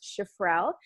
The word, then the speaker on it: Shifrel: It is my first name, Alyssa, A-L-Y-S-S-A. And then an underscore. And my last name is pronounced Shifrel, S-H-I-F-R-E-L.